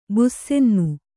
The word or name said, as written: ಬುಸ್ಸೆನ್ನು